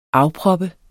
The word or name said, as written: afproppe